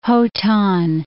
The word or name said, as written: Hotan